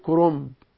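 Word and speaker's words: كرنب: اسمه كرومب، كرومب. طبعا لما تيجي تكتب كلمة كرومب كاف راء نون باء تبقى ايه كرومب، لكن لما اجي اقراها او انطقها بتقول ايه كرومب. فالكرومب ده اسمه ايه يا جماعة؟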